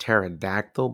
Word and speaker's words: pterodactyl: It's pronounced pterodactyl, but there's a P in front of it.